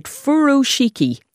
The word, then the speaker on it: Furoshiki: What do I have this right? Furoshiki. – Yeah, that's how you pronounce it.